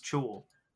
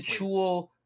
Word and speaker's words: Chuul: But, as, an English-speaking person, when I see a C and an H next to each other, it's Chuul. – Fair enough. But what about chicken? – Okay. Well, there's four ways to say No. If you go to D&D Beyond right now and go to the Chuul monster page, there is a speaker to pronounce it, and it sure as shit says Chuul.